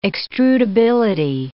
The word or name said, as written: extrudability